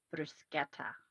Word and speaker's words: bruschetta: Now, I'm just sampling the, I got to find out, is it bruschetta or bruschetta? – I believe the correct pronunciation is bruschetta, which is very tasty.